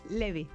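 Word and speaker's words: Levi's: ¿Es Levy o es Levy? Porque aquí yo escucho de todo, ¿eh? – Levy. – Levy. Bueno, sí. Lo dejamos sin mira que acento. Es de dos sílabas y corto, pero...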